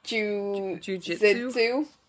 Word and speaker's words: Jiu-jitsu: Jiu-jitsu? – Yeah. – Yes, good pronunciation. Perfect. Literally spot on.